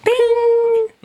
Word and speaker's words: Ping: We're sorry to be wasting your time, Ping. – You are pronouncing it a little wrong. It's like, ping.